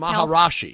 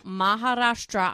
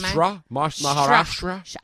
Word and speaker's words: Maharashtra: Tope, the Maharashtra Minister of Public Maharashi. – Health. No, Maharashtra. I am. – Maharashtra.